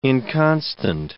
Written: inconstant

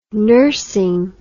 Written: nursing

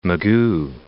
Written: Mugu, Point

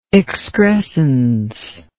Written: excrescential

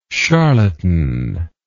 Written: charlatanically

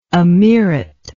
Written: ameerate

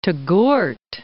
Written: Touggourt

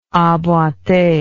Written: emboite

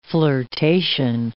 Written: flirtation

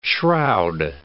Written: shroud

